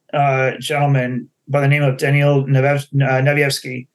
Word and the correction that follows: Nevsky: I'm hoping I'm saying his name right. I need to ask him. Nevsky. – Nevsky, what's that? – yeah. – Nevsky. Nevsky. – Nevsky. Yeah.